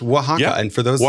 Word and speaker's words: Oaxaca: So like seeing Bryce trying to figure out how to pronounce Oaxaca, which seems so just normal to me. I just expect everyone to just know that it's Oaxaca.